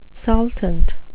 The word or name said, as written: saltant